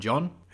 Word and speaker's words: D'John: And second thing, hello and welcome to our podcast, John and Dragons, where the first D is silent.